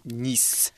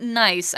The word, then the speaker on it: Nice: So it's not nice. I know the word is spelled the same as nice, but it is Nice. – Because it is a French word.